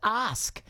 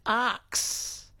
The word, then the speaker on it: ask: Michael from Bundaberg says, ask. Seems to trip up a large portion of the population who pronounce it arx.